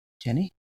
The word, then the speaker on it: Jennie: Jenny? That's how you pronounce it, right? – Yes.